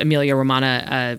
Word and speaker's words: Emilia Romagna: Yeah, it's from the Emilia Romana uh, I hope I didn't butcher that pronunciation, uh, the Emilia Romagna uh, region of Italy.